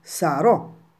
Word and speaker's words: sarò: Come vedete, sopra la lettera O c'è l'accento, quindi non possiamo pronunciare questa parola sarò, ma devo dire sarò.